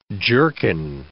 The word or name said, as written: jerkin